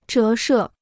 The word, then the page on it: 折射: zhé shè